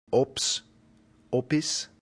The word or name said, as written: ops